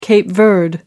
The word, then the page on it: cape verde: American English